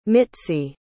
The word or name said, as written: mitzy